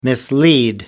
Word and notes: mislead: American English

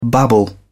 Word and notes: babble: British English

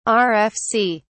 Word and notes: rfc: British English

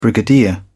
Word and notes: brigadier: British English

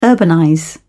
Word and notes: urbanize: British English

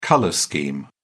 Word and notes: colour scheme: British English

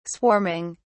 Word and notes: swarming: British English

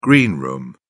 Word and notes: green room: British English

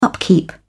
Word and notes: upkeep: British English

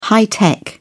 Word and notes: high tech: British English

